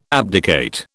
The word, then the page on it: abdicate: British English